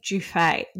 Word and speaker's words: Giuffre: You have to say Giuffre. – Do you? – Giuffre, even though it's Rossi. Spelled like giraffe.